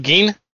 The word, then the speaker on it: Gein: Ed Gein? – Yep.